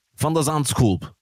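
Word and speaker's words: van de Zandschulp: Also erstmal Nadal gegen Botik von der Sandskulp wie er ausgesprochen wird.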